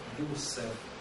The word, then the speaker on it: Юсеф: Юсеф. Юсеф. Юсеф. Юсеф. Здесь восточная Юзеф, да, а это Юсеф, буква С.